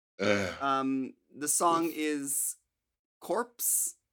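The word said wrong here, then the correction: Corps: Core. – Okay, so I guess we're not pronouncing the P or the S. – No P. – No S, just core. – It means core.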